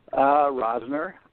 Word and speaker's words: Rosner: Rosner, R-O-S-N-E-R. – Thank you. I'm a horrible... – It's pronounced like like there's a Z, but that's fine.